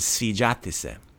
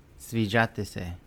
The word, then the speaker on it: sviđati se: And to like something, not just ja volim, you could say to like is sviđati se. – Sviđati se. – Yeah. Or sviđati se. There you go. – Sviđati se. – I always yell at you guys for accenting the second syllable.